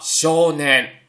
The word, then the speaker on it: 少年: 比如说森林读作心林，那么以 ng 结尾、以 ou 结尾、以 ao 结尾的话呢，都读长音。比如东京读作 Tokyo，优变读作 u 兵，少年读作 shonen。